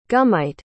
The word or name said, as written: gummite